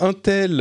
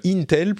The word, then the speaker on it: Intel: Il y a Intel euh, sur lequel on a des informations, euh, Intel plutôt, il faut bien prononcer,